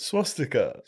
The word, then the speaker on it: swastika: How do you say it? Swash sticker. Swash sticker. That's right, isn't it? – Swastika. – Swastika. Oh, okay.